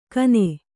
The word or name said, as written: ಖಾನೆ